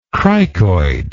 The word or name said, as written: cricoid